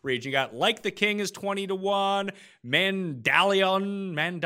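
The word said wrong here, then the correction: Mandaloun: Mandaloon. – Yeah. Dilly, dilly, dilly. Yep. Mandaloon. – Here I am trying to pronounce it like it is en français, but apparently it is not.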